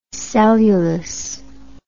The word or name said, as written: cellulous